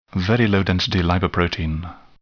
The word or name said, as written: very low density lipoprotein